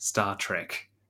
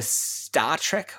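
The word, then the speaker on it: STARTRK: I will make one correction that the START-RK studies are actually pronounced Star Trek.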